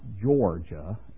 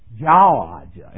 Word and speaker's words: Georgia: It is not Georgia. It's Jawajan. And I sat there, yawd. He says, just think of a jaw and say Jawajan.